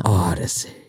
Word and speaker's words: Audacy: But that's spelled with an O. – Odyssey. – Odyssey. A-U-D-A-C-Y.